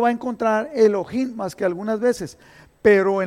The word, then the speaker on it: Elohim: Entonces, Elohim, eh, lo voy a decir Elohim, aunque la H no se pronuncia, pero como es de, como descendencia hebrea, es Elohim o Elohim, que significa Dios fuerte y poderoso, que en realidad eso es nuestro Dios.